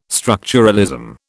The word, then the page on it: structuralism: British English